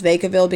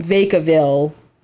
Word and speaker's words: Vacaville: I only know how to pronounce Vacaville because of Mindhunter. – There you go. – I would not have gotten Vacaville out of that.